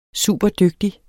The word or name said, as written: superdygtig